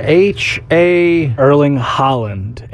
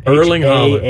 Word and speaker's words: Erling Haaland: Erling Haaland. – Erling Haaland. – H-A-A-L-A-N-D.